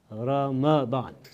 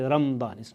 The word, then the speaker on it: Ramadan: The correct pronunciation of this month is Ramadan. Some people say Ramadan. It's with the fatha of the meme.